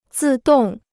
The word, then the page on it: 自动: zì dòng